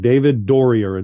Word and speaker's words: David Dorrier: David Dorrier, Dorrier. Have I got that right? – That's correct. David Dorrier. It's a German pronunciation.